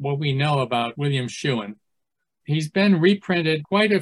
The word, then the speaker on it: Shewen: The name is spelled S-H-E-W-E-N, Schuin.